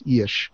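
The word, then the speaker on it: Illyes: Yeah, it's I-L-L-Y-E-S, but in Hungarian that would be pronounced Yeash.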